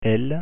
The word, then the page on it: l: French